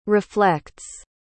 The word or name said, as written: reflects